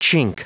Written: chink

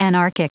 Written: anarchic

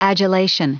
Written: adulation

